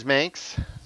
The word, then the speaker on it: Manx: The Myers Manx, rhymes with Spanx.